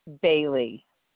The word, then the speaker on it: Bailey: Jan Bailey. – And it's B-A-I-L-E-Y? – Yeah. – And the first name is J-A-N? – Guilty.